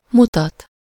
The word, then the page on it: indiquer: France; [ɛ̃.di.ke]